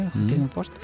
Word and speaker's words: Huffington Post: Huffington Post, ¿se pronuncia así? Huffington Post. Uh-huh.